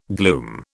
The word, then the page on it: gloom: British English